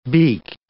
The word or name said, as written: beaked